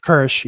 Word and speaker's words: Kersh: Yes, Min Kwan, played by Charlie Kersh. I hope I said that right. – Kersh, yes.